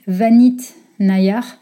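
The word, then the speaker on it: Vineet Nayar: Je crois que ça se dit euh, Vanit Nayar. Ça s'écrit V-I-N-E-E-T, plus loin Nayar, N-A-Y-A-R.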